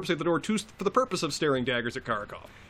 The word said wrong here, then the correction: Karkaroff: particularly when I'm pronouncing right Karkaroff. – Karkaroff, yeah.